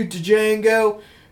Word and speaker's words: Django: One of the guys like, "Damn you, Django!" And then he goes, "The D is silent, hillbilly," and then shoots him.